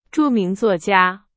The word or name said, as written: 著名